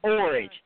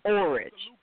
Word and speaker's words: Orage: Okay, it's Orange. It rhymes with storage, so it's Orange.